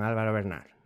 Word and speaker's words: Álvaro Bernad: Nos acompaña además una estrella invitada que es el maese don Álvaro Bernal. Lo he dicho bien. – Perfectamente, sí.